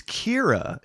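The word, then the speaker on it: Qi'ra: It's Kira is the other one, and that is from Solo, a Star Wars story.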